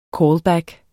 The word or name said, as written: callback